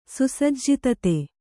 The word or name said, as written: ಸುಸಜ್ಜಿತತೆ